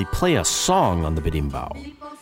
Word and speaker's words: berimbau: And we then hear a brief interlude played on a berimbo. Which is neither a guitar, drum set, nor mellotron, so we know nothing about it. Producer Mike, what's a berimbo? – It's actually pronounced berimbau, and it is a Brazilian instrument believed to have originated in southern Africa.